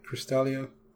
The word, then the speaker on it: Crystallia: I mean, there's the whole, there is the, you know, Crystallia and Earth, like, discovering about this Crystallia and, you know, am I saying that right? Crystallia? – Crystallia, yeah, that's right.